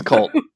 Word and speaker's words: KVLT: It's cult. Cult, guys. KVLT is pronounced cult.